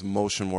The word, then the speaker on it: Mocean: The artist's name is Motion Worker. It's spelled MOCEAN Worker. So M-Ocean, Motion Worker.